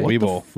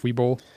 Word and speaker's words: Webull: Webull. – Webull.